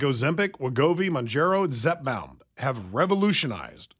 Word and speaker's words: Wegovy: Is it Wegovy? Is that how you pronounce Wegovy? – It? – Yeah.